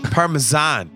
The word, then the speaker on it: Parmesan: Parmesan. Parmesan is actually the correct pronunciation.